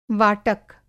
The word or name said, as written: वाटक